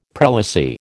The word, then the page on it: prelacy: American English